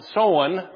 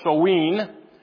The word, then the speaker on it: Samhain: Further, they believed that on October 31st, and that's actually looks like Samhain, but they pronounce it Soen or Soween.